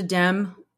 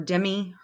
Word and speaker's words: Demme: Amanda Demme, or Demme, her last name is spelled D-E-M-M-E.